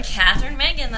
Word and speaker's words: Catherine Mangan: Catherine Mangan, that's Catherine Mangan, all right.